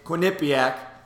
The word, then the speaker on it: Quinnipiac: Quinnipiac. That's how you pronounce it.